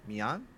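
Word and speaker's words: Meian: there's a French publisher by the name of Mian. Am I pronouncing that Yes. – correctly? – Yeah, you are.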